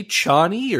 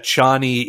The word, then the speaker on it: Chani: And my understanding is that Chani is the correct, like, if you're reading English, but Frank Herbert said Cheney.